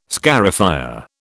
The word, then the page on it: scarifier: British English